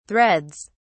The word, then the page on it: threads: British English